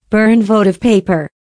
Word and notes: burn votive paper: American English

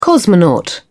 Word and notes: cosmonaut: British English

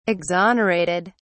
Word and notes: exonerated: British English

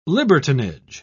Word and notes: libertinage: British English

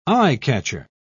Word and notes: eye-catcher: British English

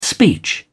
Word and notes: speech: British English

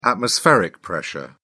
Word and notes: atmospheric pressure: British English

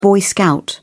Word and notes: boy scout: British English

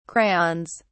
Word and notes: crayons: British English

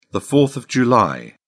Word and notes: fourth of july: British English

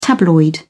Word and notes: tabloid: British English

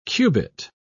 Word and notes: cubit: British English

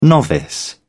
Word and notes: novice: British English

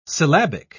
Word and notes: syllabic: British English

